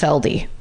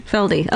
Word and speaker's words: Felde: His name is Wayne Robert Feld, pronounced Feldy. I couldn't know until I got to that part of the parentheses. – F-E-L-D? – F-E-L-D-E. – Feldy.